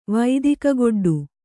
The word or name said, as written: ವೈದಿಕಗೊಡ್ಡು